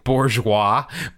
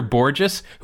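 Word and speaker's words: Bourjos: I want to say Bourgeois, but no, Peter Borges, who I believe was released like a week ago.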